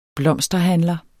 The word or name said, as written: blomsterhandler